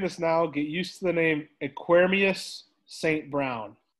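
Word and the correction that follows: Equanimeous: Oh, no, you mean Equanimius. – Is that how you say it? – The Notre Dame guy? – Yeah. – Yeah, I think it's Equanimius St. Brown.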